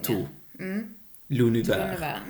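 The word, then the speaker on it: tout l'univers: Men T-O-U-T. To skulle jag uttala det. Det låter ju som någon funger. – To. – Lunivär.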